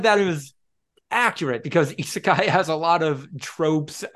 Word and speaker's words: isekai: It'd be like saying I don't like isekai. – Sure. – Did I get that right? – Did I pronounce that correctly in the yes.